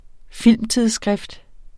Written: filmtidsskrift

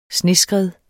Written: SNESKREDDENE

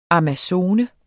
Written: amazone

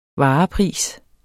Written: varepris

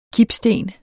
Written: kipsten

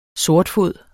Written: sortfod

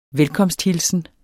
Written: velkomsthilsen

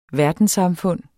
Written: verdenssamfund

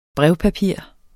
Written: brevpapir

